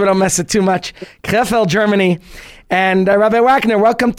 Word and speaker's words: Krefeld: And ladies and gents, please join me in welcoming Rabbi Yitzchak Wagner, who is the shliach of the Rebbe to the city Krefeld. Is that correct? Is that how you pronounce it? – Yeah, you made a very good job. Even though most people can't pronounce it correctly, if it's hard for you, I always say you can call it Kreplach.